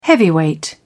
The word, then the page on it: heavyweight: British English